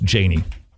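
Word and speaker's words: Jani: This is a great quote from I believe it's pronounced Janie, Janie Ortland. It's J-A-N-I.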